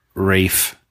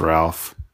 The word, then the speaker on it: Rafe: Colin Farrell and Colin Farrell and Academy Award nominee Rafe, not pronounced Ralph, Rafe Fiennes.